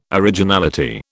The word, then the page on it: originality: British English